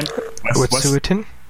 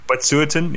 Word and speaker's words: Wet'suwet'en: So they are Native American who are sort of standing up in solidarity with how do you pronounce it again? – Wet'suwet'en? – Wet'suwet'en, yes. With the Wet'suwet'en natives.